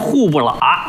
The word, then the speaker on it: 虎伯劳: 哎，老北京本地发音户不喇。哎，这写出来呀叫伯劳啊，老虎的虎，虎伯劳，念出来户不喇。